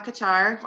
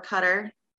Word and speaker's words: Qatar: And so we went overseas to Doha, Qatar, or Qatar. Different ways of pronouncing it.